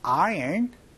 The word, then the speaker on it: iron: 근데 아이롱이 아니고요. 영어 발음은요. 아이언이라고 얘기를 합니다. 그래서 I don't know how to iron clothes 이렇게 말씀해 주시면은 그쵸?